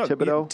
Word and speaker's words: Thibodeaux: Is, is that how you say his name? Am I yeah, right with that, know, Thibodeaux? Yeah, Thibodeaux's perfect.